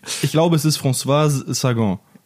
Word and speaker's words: Sagan: Bonjour Tristesse von François Sargent reden. Jetzt habe ich es falsch gesagt. – Ne? Das ist falsch gesagt. Sollen wir mal? Ja. Franz. wir wollen. Wir wollen über Bonjour Tristesse von François Sagan reden. War das jetzt richtig? Ich, ich weiß, François Sagan.